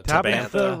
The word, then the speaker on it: Tabantha: Look in the Tabantha? Is that how you pronounce it? – Tabantha region.